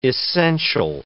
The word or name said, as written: essential